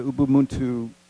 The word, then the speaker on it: Ubuntu: But we want to talk, let's get right into Rwanda and the Ubuntu Arts Festival, and I hope I'm pronouncing that right, because that is correct.